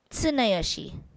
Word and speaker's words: Tsuneyoshi: Because how do you say it? – Tsuneyoshi. – I say it Tsuneyoshi. – Why do you say it with an S, like su? – Su, tu, tu, tu. – Yeah, Tsuneyoshi.